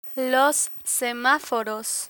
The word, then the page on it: semaforos: spanish